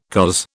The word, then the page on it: coz: British English